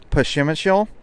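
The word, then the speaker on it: Przemyśl: You have to take a train to the town of, it's spelled really weird, but it's pronounced Pashimishl.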